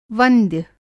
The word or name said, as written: वंद्य